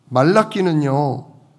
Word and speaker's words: 말라키: 좀 더 정확한 발음은 말라키. 그렇습니다. 자, 지금 말라키는요.